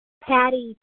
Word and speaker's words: Pattie: Patty T. – Could you spell it for me, please? – P-A-T-T-I-E.